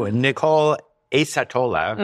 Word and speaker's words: Nichole Accettola: So, Nichole Accettola, mm-hmm.